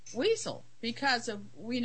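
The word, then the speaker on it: Wiesel: No, it's Weasel. I-E would be on the E. Would actually. – Okay. I'm just saying Weasel as in the animal Weasel. – Well, that's how it's pronounced. – Oh, is it? – Yes. – Oh, okay. – Yeah. Actually, it's Weasel. Weasel. – Oh, okay. – I mean, yep. if you know, that's how he pronounces his name.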